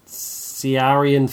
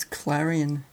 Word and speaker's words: Ciarán: Ciarán Foy? – I think it's Clarion. – There's no L, that's an I.